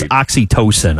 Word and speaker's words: oxytocin: He also said it's oxytocin. I guess that's how it's pronounced.